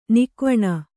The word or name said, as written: ನಗೆವೆಣ್ಣು